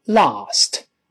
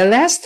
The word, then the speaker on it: last: The last time 就是上一次，注意那个 last，l a s t，英国人读成 last，它发啊，但这个啊的声音呢，在美音中多数都会变成 a，口型最大的这个 a。你像这个这个回答，英国人说 answer，美国人变成 answer。呃，跳舞，英国人读成 dance，美国人读成 dance，这口型会变大。